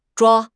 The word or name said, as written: zhua1